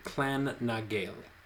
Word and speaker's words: Clan na Gael: I think it's the Clan na Gael. – Okay, yeah, that looks correct.